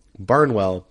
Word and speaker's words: Barnwell: So download that SeatGeek app, enter in my promo code, which is my last name, Barnwell, B-A-R-N-W-E-L-L, right there on the name of the podcast.